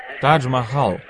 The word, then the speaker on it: Тадж-Махал: И я стоял в гостинице в Тар-Маджал, по-моему, так. Как это произносится? Как это произносится? Гостиница Тар-Маджал? Тадж-Махал.